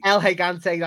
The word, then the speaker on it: El Gigante: Was that how you pronounce it? El Gigante. El Gigante, that's the one.